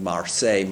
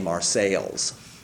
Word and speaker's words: Marseille: I mean, you know, but he insisted on calling Marseille, Marseilles. And Lyon, Lyons. Because that's the way it's pronounced in Britain.